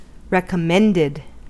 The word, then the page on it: recommended: American English